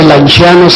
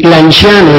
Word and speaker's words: Lanciano: Bueno, pues Lanciano se pronuncia así, la C se pronuncia como che suave, para decir Lanciano. Y Lanciano está distante de Roma 280 kilómetros, en una de las autopistas más hermosas que yo conozco.